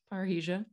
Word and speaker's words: parrhesia: Well, and moving forward, you wrote the poem that, for lack of better words, went viral, called Parhesia. Yeah. Am I saying that right? – Yeah, Parhesia.